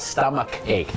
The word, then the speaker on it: stomach ache: stomach ache C H อาจจะเป็นชอช้างแต่ C H ก็เป็นคอควายก็ได้นะครับ stomach ache นั่น